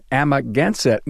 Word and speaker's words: Amagansett: Hey, a warm welcome to Eric Bock from Bock Auto in Amagansett, New York. Did I say that right? – Yes, you did.